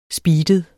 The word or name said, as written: SPEEDEDE